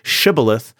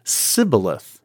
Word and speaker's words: shibboleth: Well, it turns out, for whatever reason, that the Ephraimites have a dialectical quirk where they cannot say shibboleth, but instead pronounce it sibboleth.